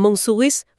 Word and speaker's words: Montsouris: Tại trạm Montsouris,